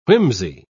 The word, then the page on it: whimsey: British English